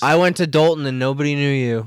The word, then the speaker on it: Dolton: It's pronounced Dalton. – But it's spelled Dolt? – Yeah, D-O-L-T-O-N.